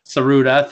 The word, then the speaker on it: Tsuruta: Mike will get a pop out of this because I can never pronounce names, but he beat Jumbo Tsuruta for the belt. – Saruda, thank you. Saruda. I can't do it. – The T is silent.